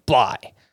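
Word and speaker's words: Bligh: If you're trying to figure out how to spell it, it's B-L-I-G-H. That's his first name, Bly.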